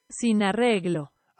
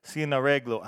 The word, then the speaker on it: Sin Arreglo: I don't think I've got that one correct, but according to Google Translate, it goes from Spanish to English as no arrangement, pronounced in Spanish according to Google Translate. – Sin arreglo. – Arreglo. Sin arreglo.